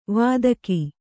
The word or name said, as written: ವೈದಿಕ